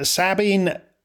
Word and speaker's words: Sabine: And then I found out that Sabine Baring-Gould was a man. He'd been named Sabine after his grandmother's family. And in fact, they pronounced it not in the French way, but in the Anglo-Irish way, Sabin.